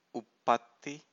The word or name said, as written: uppatti